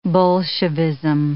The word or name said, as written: Bolshevism